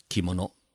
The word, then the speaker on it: 着物: 日文念作是 kimono，但是在古代日语当中啊，和服还有一个名称叫吴服。